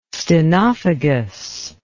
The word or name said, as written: stenophagous